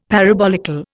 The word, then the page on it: parabolical: Indian